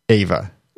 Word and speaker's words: Eva: Right off, we discovered that Queen Ava is actually, E-V-A is actually pronounced Ava.